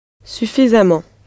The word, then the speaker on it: suffisamment: Now, if you add this ment ending to an adjective already ending in ent or ant, the new ending becomes emment or amment, both pronounced amant. So, if sufficient is suffisant, sufficiently will be suffisamment.